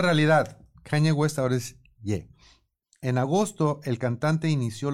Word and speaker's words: Ye: Kanye West se ha cambiado legalmente de nombre por Ye. – Ye. – Ye, que es la última sílaba de Kanye.